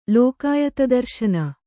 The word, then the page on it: ಲೋಕಾಯತದರ್ಶನ: Kannada